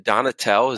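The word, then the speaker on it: Donatell: is it Donatel? Is that how you pronounce his last name? – Yeah, I think you got it.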